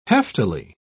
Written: heftily

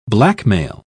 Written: blackmail

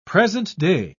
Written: present-day

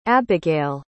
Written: abigale

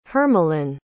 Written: hermeline

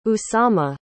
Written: usama

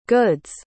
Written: good's

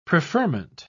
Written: preferment

